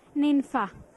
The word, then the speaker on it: Ninfa: T-O-N-Y. – And your name? – Ninfa. – Ninfa, can you spell that? – N-I-N-F-A.